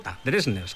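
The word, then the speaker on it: Dversnes: Lo de Dresners hay que explicarlo un poco, porque su apellido se escribe con deletreando D de dedo, V de Valencia y luego Esners, ¿vale? Pero al parecer en noruego, pues esa DV se pronuncia como una DRJ, Dresners, que es como él mismo lo dice,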